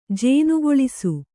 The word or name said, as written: ಜೇನುಗೊಳಿಸು